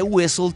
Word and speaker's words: whistle: Y ese whistle tiene por ahí una H que no se siente, o sea que es muda.